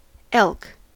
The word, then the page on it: elk: American English